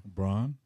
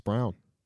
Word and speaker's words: Braun: Christian Brown? – What about him? – Braun? – It's Brown.